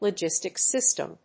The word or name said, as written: logistics system